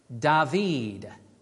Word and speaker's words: David: The bottom word is pronounced David. Get it? It's the name David in Hebrew.